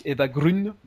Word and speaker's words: Eva Green: Alors, je crois que ça se prononce Eva Grün, il me semble, puisque son père est, d'origine suédoise, mais c'est la fille de Marlène Jobert.